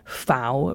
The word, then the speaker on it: FAO: So the Food and Agriculture Organization, the FAO, I pronounce it the way that they pronounce it in Italy because the headquarters are in Rome. FAO rhymes with cow.